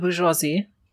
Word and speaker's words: bourgeoisie: the the bourgeois the, the bourgeois the, how do you say that again? – The bourgeoisie.